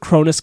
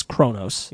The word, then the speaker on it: Cronus: I'm going with Cronus because that's how it's said in Theogony. – Yeah. – But I also have many times known him as Cronos.